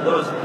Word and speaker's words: درست: هكذا باللغة الكردية والفارسية يهجون دام دال ضمة وراء ضمة وسين ساكن وتاء موقوف درست، لا يقول درستن، كل يعني كلمة ينبغي أن تطلق على نطقهم إلا إذا كان العرب غيروه ببعض الكلمات وعربوه.